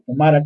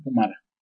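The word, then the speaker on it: Pumyra: Y la introducción de Pumira, o Pumara, como se dice, bueno, se escribe Pumira con Y, y se pronuncia tanto en inglés como en español, Pumara.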